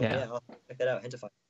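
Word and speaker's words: Gentefied: It's like, it's spelled with a G, gentified, but it's like pronounced hentified.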